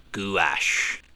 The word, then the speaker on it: gouache: She creates illustrations using, and I check to see how this was pronounced, it's gouache. Yes.